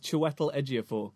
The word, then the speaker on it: Chiwetel Ejiofor: Yeah, as far as I understand, it's Chiwetel Ejiofor. – Yeah, there's something about the pronunciation of the first I in there that I've heard him pronounce it. – And oh, what would he know? – And it sounds like a diphthong, but I'm not going to dispute it.